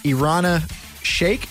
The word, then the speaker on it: Irina Shayk: Bradley Cooper and his girlfriend, Irina Shayk.